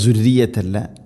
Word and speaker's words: ذريتي: ദുർറിയത്തി പറയരുത്, തർക്കീഖ് ചെയ്യണം, നേർപ്പിക്കണം. ദുർറിയത്തി ദാലിൻ്റെ ഉച്ചാരണം ശ്രദ്ധിക്കുക, ദുർറിയത്തല്ല ദുർറിയത്തി.